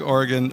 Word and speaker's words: Oregon: Oregon? Oregon? How do you say it? – Well, I'm from California originally, so however I say it, I'm told I'm wrong. But no, Oregon. – Oh, I see. Oregon.